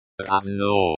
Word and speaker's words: Равно: Равно. Pronounced like равно, with the O, as in go.